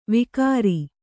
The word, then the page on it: ವಿಕಾರಿ: Kannada